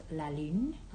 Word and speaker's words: la lune: In French is La Lune.